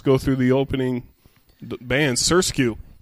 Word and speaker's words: SRSQ: And her name is Surskew. It's spelled S-R-S-Q.